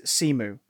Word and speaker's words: Simu: I love the idea that in the comics there was a period where the proper pronunciation is Simu.